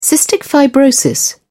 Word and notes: cystic fibrosis: English; British English

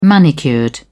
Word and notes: manicured: British English

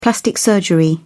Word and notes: plastic surgery: British English

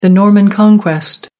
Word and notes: norman conquest: American English

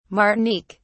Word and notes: martinique: British English

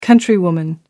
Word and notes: countrywoman: American English

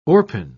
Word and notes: orpin: British English

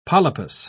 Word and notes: polypous: British English

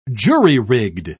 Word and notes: jury-rigged: British English

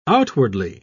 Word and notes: outwardly: British English

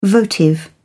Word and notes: votive: British English

